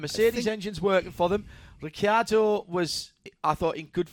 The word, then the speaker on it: Ricciardo: McLaren Mercedes, Lando in second, and Daniel Ricciardo, which that is the proper way to say it, even though he doesn't say it like that, because he can't be bothered. – Yes, too many syllables.